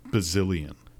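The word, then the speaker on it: Bazilian: As you said, Eric Bazalan. His name is actually Eric Bazillion.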